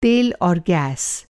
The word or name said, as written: तेल